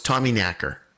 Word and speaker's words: Tommyknocker: It is pronounced knacker. – Ah. – So it's Tommyknacker.